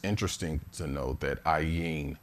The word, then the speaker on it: Ayin: And that means 74, Ayin meaning, it's a silent letter.